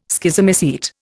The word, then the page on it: Schizomycete: Indian